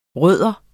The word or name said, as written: rod